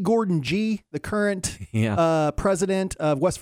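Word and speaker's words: Gordon Gee: Fun fact, it's a hard G. It's E. Gordon G. Gordon G.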